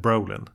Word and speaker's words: Brolin: man ut uttalar jag det namnet rätt om jag säger Brolin? Det gör du nog.